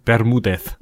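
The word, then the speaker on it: Bermúdez: Ber Bermudez. Bermudez, so würde ich es aussprechen, der Juan. Schön.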